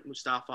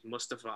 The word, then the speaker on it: Mustafa: I am so in on Mustafa Ali. – Yes. – You said his name wrong. – It's Mustafa. That's right.